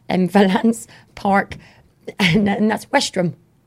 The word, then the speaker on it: Westerham: It's pronounced Westrum. Trust me on this.